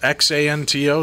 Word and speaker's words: Xanto: Uh, am I saying that right? Is X A N T O Xanto? I believe so, yeah. All right.